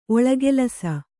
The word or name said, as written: ಒಳಗೆಲಸ